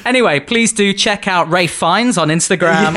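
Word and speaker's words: Ralph Fiennes: I really want to get to the fame levels of Rafe Fines, which is, you know, spelt Ralph, but pronounced Rafe.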